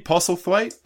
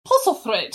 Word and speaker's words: Postlethwaite: Pete Postlethwaite? – Pete, yeah, I can never pronounce his last name correctly, but he is fantastic in this. – You gotta say it very English. Postlethwaite.